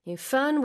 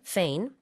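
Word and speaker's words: foehn: 대체적으로는 이제 fern, fane 다 가능한데 이 fan 현상 때문에 어떻게 보면은 강릉에서 이렇게 열대야를 겪게 됐는데요.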